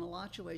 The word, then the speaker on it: Alachua: And in 1949, they built a house on Alachua Street between 16th and 17th Street. And I must tell you, the real pronunciation of A-L-A-C-H-U-A is Alachua.